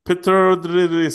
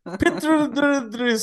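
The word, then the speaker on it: Pittodrie: på kan någon uttala vad eh, arenan hette? – Dory. – Petrodri Stadium. Petrodri Stadium.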